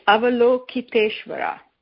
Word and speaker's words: Avalokiteshvara: It's Avalokiteshvara.